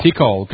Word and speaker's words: Tikal: T-I-K-A-L. It's in Guatemala. – Tikal, got you.